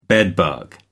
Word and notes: bedbug: American English

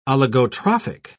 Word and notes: oligotrophic: British English

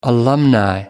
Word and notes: alumni: British English

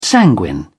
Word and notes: sanguine: British English

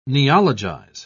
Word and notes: neologize: British English

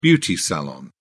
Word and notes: beauty salon: British English

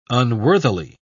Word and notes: unworthily: British English

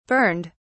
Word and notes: burned: British English

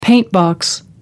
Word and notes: paintbox: American English

